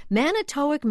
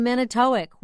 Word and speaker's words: Manitowoc: How could I say Manitowoc when it's Manitowoc? – Greg sent us this Merriam-Webster pronouncer.